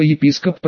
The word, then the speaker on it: епископ: Например, лет до 10 я был уверен, что слово «епископ» произносится с ударением на букве «о». Да как же иначе, есть слова «микроскоп», «фильмоскоп», «телескоп». Значит, и «епископ» должен произноситься так же.